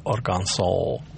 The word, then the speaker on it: Arkansas: Вот Кот утверждает, что слово Арканзас должно произноситься как Arkansas. – Да. – Но местные так говорят.